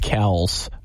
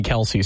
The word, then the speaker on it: Kelce: He said, guys, my name is actually pronounced Kels, but everyone's called me Kelce, so I guess I'm doing that.